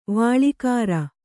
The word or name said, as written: ವಾಳಿಕಾರ